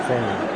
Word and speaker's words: salmon: Salmon. It's a salmon. – There's a silent L in salmon, ladies and gentlemen.